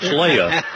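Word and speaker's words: Leia: It's spelled L-E-I-A. I would call that Leah, but no, it's Leah. You know, you get to pronounce it the way you want to, and it's Leah.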